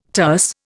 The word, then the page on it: terce: Indian